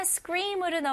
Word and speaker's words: scream: 여기에서 s, k, r 이 발음을 다 연결시켜 줘야 돼요. scream. okay? 그리고 e 소리가 나야 돼요.